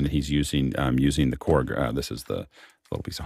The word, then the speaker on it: Korg: On Wednesday, we've got a lab, and I hopefully will not butcher this, but it's Core. Is it Core? – Korg. Korg.